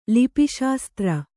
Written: ಲಿಪಿಶಾಸ್ತ್ರ

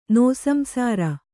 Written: ನಿಸ್ಸಂಸಾರ

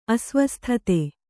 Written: ಅಸ್ವಸ್ಥತೆ

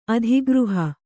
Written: ಅಧಿಗೃಹ

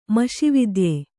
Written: ಮಷಿವಿದ್ಯೆ

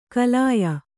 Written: ಕಲಾಯ

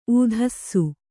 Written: ಊದಿಸು

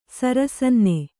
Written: ಸರಸನ್ನೆ

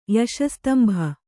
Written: ಯಶಸ್ತಂಭ

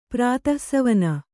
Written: ಪ್ರಾತಃಸವನ

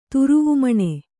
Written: ತುರುವುಮಣೆ

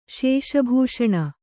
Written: ಶಶಿಭೂಷಣ

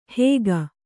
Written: ಹೇಗ

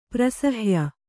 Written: ಪ್ರಸಹ್ಯ

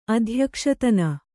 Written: ಅಧ್ಯಕ್ಷತನ

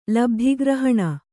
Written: ಲಬ್ಧಿಗ್ರಹಣ

